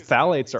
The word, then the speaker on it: phthalates: You know, because phthalates are also used. And by the way, phthalates are spelled P-H-T-H-A-L-A-T-E-S, but it's pronounced phthalate, like just T-H. – Okay. – The P-H is silent.